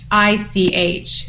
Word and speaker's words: Bašić: It's Bašić.